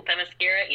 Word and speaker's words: Themyscira: Oh, Themyscira, yeah. I mean, yeah. – yeah. – The best we're gonna get on this podcast. – Yeah, true. But it's like I said, it says hell, formerly Themyscira,